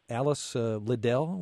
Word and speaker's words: Alice Liddell: and this is a book that's about Alice Liddell.